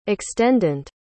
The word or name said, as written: extendant